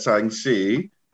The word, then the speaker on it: Shang-Chi: It's officially that Shang-Chi, as properly pronounced.